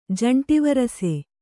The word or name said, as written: ಜಂಟಿವರಸೆ